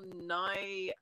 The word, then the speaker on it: Nighy: this is Bill Nye. It's actually N-I-G-H-Y is how it's spelled. – Oh, not N-Y-E. – No, he's British. – Oh, But it's pronounced the same, so...